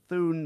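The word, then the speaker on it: Thune: Thun, T-H-U-N, is that right? Thun, or it's Thun, right? H is silent.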